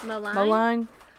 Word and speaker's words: Moline: Is it M-I-L-A-N? – M-O-L-I-A. Oh my gosh. M-O-L-I-N-E. – Malign, I guess. – Malign. – Malign. Yeah, Malign.